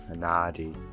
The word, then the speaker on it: Anadi: I leaned on the long A, the R, Anadi.